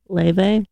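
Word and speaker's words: Laufey: People compare her in like Lufe a lot. – Mm-hmm. Yeah, Lufe. – Is that how you say it? – Mm-hmm. – Lufe? – It's Icelandic. – Will you say it again? – Leve.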